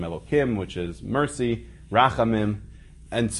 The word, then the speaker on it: Elokim: We pronounce it, even though it's spelled with four letters, we pronounce it Elokim.